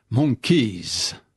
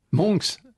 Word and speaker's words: monks: Et dans son anglais parfait, il dit 500 monkeys, c'est-à-dire en anglais des singes. Monks, c'est les moines, Simon. Mais il prononce monkeys.